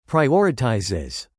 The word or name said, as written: prioritizes